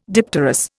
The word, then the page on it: dipterous: Indian